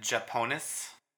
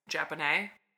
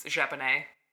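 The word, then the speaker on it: japonais: At Japonis? – Japone. – It was J-O-J-P-O-N-I-S. – It's the French word for Japanese. It's Japone.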